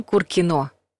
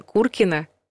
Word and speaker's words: Куркино: Район Куркино. Неправильный вариант. Район Куркино.